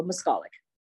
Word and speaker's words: Moskalik: Moskalik? – It's on the second syllable, Moskalik.